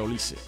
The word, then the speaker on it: Olise: Algunos le dicen Olise, pero realmente su apellido se pronuncia Olise.